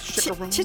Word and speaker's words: chicharrones: Is that how we say that? – Chicharrones? Chicharrones, according to Google pronunciation.